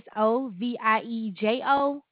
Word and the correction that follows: Aliso Viejo: Oh, Aliso. Aliso Viejo.